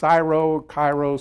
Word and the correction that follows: cryo: something or other chamber. You say, what is that? It's this, what is it? Cryo chamber.